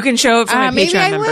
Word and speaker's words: Patreon: Or maybe for her Patron, or am I saying it right? Patreon? – Patreon. – Patron. I'm thinking of tequila.